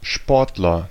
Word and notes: Sportler: /ˈʃpɔʁtlɐ/; /ˈʃpɔrt.lɛr/

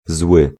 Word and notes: fâché: [fa.ʃe]; /fɑ.ʃe/

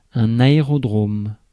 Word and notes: aérodrome: France; [a.e.ʁɔd.ʁom]

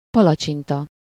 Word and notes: palacsinta: /ˈpɒlɒt͡ʃintɒ/; /pɒ.lɒ.ʧin.tɒ/

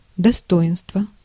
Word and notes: достоинство: /dɐˈstoɪnstvə/

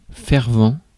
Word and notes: enthusiastic: /ɪnˌθjuːzɪˈæstɪk/; /ɪnˌθjuzɪˈæstɪk/

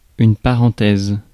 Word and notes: parenthèse: /pa.ʁɑ̃.tɛz/

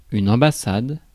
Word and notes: ambassade: France; /ɑ̃.ba.sad/

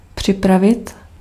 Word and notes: prepare: American English; [prɪˈpɛə]

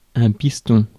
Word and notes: connection: American English; [kə.ˈnɛk.ʃən]

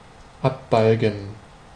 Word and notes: abbalgen: /ˌapʔ.ˈbɑːl.ˌŋən/

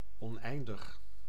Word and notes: infini: France; /ɛ̃.fi.ni/

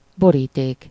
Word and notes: enveloppe: France (Paris); [y.n‿ɑ̃v.lɔp]

